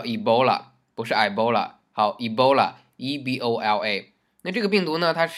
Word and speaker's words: Ebola: 大家想埃博拉，再一看它的拼写 ,EBOLA,EBOLA, 我们就想啊、哦、是不是读成 Ebola? 啊错，它不是 Ebola, 它的英文名叫做 Ebola,Ebola。